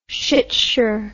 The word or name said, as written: shitsure